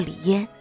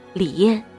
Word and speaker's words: 李燕: 李嫣答：“ 我是一九四三年生于北京，所以我的父亲苦禅老人给我起名叫李嫣，燕京的燕，别念成李燕。”